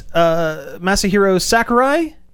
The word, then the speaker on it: Masahiro Sakurai: Nintendo and Masahiro Sakurai... Did I pronounce that correctly? – Yes. Yes. Yeah, you nailed good.